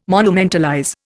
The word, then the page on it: monumentalize: Indian